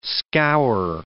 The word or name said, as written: scour